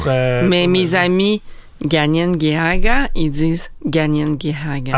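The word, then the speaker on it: Kanien'kehá:ka: Mais j'ai entendu quelqu'un le prononcer. – Oui. – Puis c'était une personne issue du premier peuple, puis elle disait Kanikahaga.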